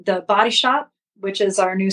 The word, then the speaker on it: BOTI: We should say BOTI is how Body is spelled.